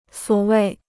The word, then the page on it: 所谓: suǒ wèi